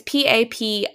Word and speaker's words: Papier: My next one, I think you pronounced the brand Papier. – That's what I would say, yeah. – Papier. It's Papier, and it's their wellness journal.